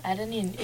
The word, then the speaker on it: adenine: Adenine, or is that how you say it? – Adenine. You know, that's adenine.